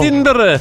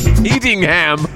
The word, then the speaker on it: Edinburgh: Edinburgh. – That's an Edinburgh label. – Edinburgh. Eddingham. The Americans say.